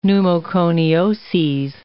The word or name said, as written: pneumoconioses